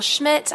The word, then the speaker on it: Schmidt: My name is Rachel Schmidt. I'm here, that's spelled S-C-H-M-I-D-T.